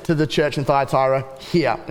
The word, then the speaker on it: Thyatira: Thyatira. – Thyatira, thank you.